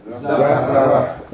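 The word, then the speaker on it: Zahratun (زهرة): Zahratun. Zahratun. Three syllables. Zahratun. Zahratun. Tun. If we stop on this word, we pronounce it? – Zahra. – Zahra. Zahra. Naam.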